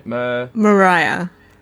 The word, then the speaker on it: Mariah: Another thing that's great about Maria. Am I saying her name right? – Mariah. – Mariah. – Like Mariah Carey. – Like Mariah Carey. Excellent.